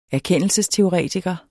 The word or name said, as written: erkendelsesteoretiker